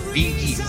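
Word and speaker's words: Bee Gees: No que sea que estemos viejos, que ahora que está puesto, no, de los BG se escribe, pero se pronuncia Biggie.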